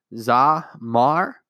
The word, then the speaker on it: Zamar: So the word Zamar, and that's pronounced Zamar. So that's Z-A-M-A-R, and you may have seen it when you clicked onto this podcast episode.